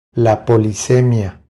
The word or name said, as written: La polisemia